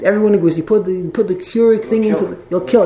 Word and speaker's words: Keurig: Am I pronouncing that correctly? – Keurig. – The Keurig? – Keurig.